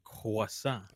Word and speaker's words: croissant: The correct pronunciation would be croissant.